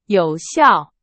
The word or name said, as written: 有效